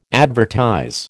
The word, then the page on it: advertise: American English